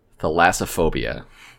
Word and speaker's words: thalassophobia: It's pronounced thalassophobia.